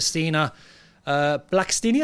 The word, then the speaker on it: Stina Blackstenius: Arsenal win the Women's League Cup by beating Chelsea in extra time thanks to Stina Blackstenius.